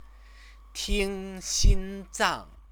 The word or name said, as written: tingxinzang